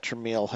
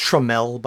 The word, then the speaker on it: Tramiel: And it's pronounced Tramiel, by the way. – Well, Tramiel, sorry.